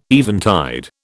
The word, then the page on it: eventide: British English